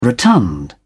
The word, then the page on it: rotund: British English